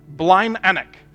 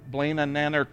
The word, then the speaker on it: Blind Anak: Blind Anak is how you say that. And you have to, Anak. Blind Anak. I want to say like Blaine and Nanark or something like that. But it's Blind Anak. And I asked several times to make sure, like, am I pronouncing this right from when we were there? Blind Anak, yes, Blind Anak.